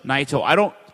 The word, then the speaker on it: Naito: So rounding out the card real quick, your heavyweight title match was Okada retaining his title, beating Tetsuo Naito. Am I saying that right? – Naito.